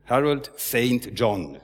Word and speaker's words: Harold St. John: Harold Saint John, also St. John, spricht man das aus.